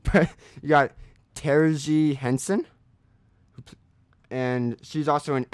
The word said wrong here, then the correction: Taraji: Let's try that again. Spell it for me. – T-A-R-A-J-I. – Okay. I've heard the name said. I don't want to blow it. It's Taraji.